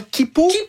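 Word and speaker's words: Quipu: Et si vous pensiez que c'était déjà vertigineux d'imaginer l'Aniakea, attendez qu'on vous parle de comment Kipu. Ça s'écrit Kuipu. – Oui. Donc Kipu, c'est deux fois plus grand que l'Aniakea.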